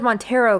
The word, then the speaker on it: Montero: I thought it was Montero because Montero. – Yeah, I think his real name is Montero. Oh.